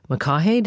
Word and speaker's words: McCaughey: Betsy McCaughey, did I say her name right? – I believe that's okay.